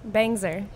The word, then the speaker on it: Bangser: Is it Bangser or Bangser? How do you pronounce the S? – Bangser.